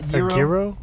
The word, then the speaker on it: gyro: I believe Is that how you say it? Gyro? – Yeah, gyro.